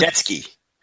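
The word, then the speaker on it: Mordetzky: I forgot how to pronounce his last name, but the former Chris Masters. – Mordetsky. – Yeah, Mordetsky.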